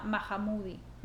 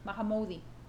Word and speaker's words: Mahmoudi: Mahamudi. Mahamudi. ¿Cómo se escribe? Es M-A-H-M-O-U-D-I.